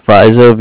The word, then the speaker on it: Vizzo: That's Fizo, V's and Victor, I-Z-Z-O. – Thank you. That's spelled Kyle, K-Y-L-E, Fizo, V-I-Z-Z-O.